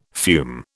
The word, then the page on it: fume: British English